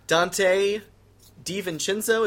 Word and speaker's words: Dante DiVincenzo: You ended up getting, Dante DiVincenzo. Is that how it's pronounced? – You nailed it.